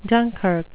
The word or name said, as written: dunkirk